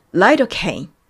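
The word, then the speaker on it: lidocaine: リのところがライという風に読まれまして、そして、えー、リドカイン、カインのところがケイという風に発音されていました。つまり、ライドケイン。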